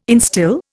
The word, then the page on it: instil: Indian